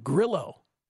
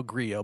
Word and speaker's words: Grillo: Emiliano Grillo, which, by the way, over the years we've pronounced Grillo, but apparently it's Grillo, or apparently he says...